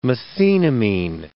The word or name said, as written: methenamine